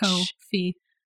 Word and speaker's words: Ko-fi: Coffee is spelled K-O-F-I, like which? – Co-fee.